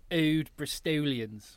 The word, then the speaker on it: Old Bristolians: Yeah, so you said that wrong. It's Old Bristolians.